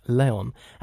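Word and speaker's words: Léon: And he goes on to say that that pronunciation is in fact correct with the accent Leon. As Leon. This is how he is referred to in the film by Danny Aiello.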